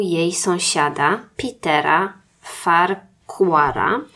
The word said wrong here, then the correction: Farquhar: Peter Farquhar, mam nadzieję, że jego nazwisko wypowiadam dobrze, jak nie, to sorry. – Wymawiasz tak, żeby każdy mógł sobie zgooglać. – Dokładnie. Nie, to by było Farquhar.